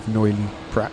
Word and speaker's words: Noilly Prat: I believe you pronounce a T on the end of Noilly Prat.